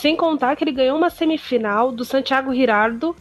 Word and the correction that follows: Giraldo: que é outro que tá voltando pro tênis, o Santiago Giraldo, né? Desculpa, gente.